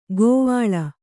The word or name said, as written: ಕೋವಳ